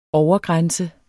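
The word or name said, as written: Overgrænse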